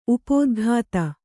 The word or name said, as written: ಉಪದಿಗ್ಧ